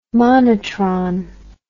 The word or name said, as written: monotron